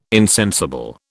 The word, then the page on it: insensible: British English